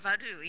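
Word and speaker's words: Vodou: And tonight's episode of Constantine is episode 5, Danse Vadou? – Vadou, yes. – Okay, I am pronouncing it correctly.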